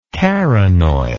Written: carronoil

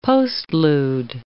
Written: postlude

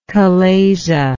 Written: chalazian

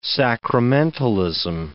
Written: sacramentalism

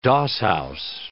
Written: doss-house

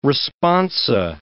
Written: responsa

